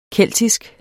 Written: keltisk

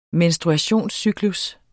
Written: menstruationscyklus

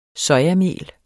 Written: sojamel

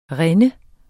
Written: rinde

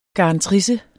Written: garantrisse

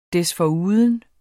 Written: desforuden

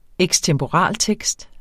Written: ekstemporaltekst

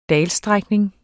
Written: dalstrækning

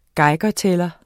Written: geigertæller

